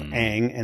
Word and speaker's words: Aang: His name's Aang, and I guess if you were in Asia, Japan, or in China, you would probably pronounce that Aang. But it is in the series they call him Aang. And he calls himself Aang, and it's Aang.